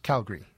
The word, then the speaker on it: Calgary: But it's just Calgary. Oh, there's Calgary. There's, there's no middle syllable.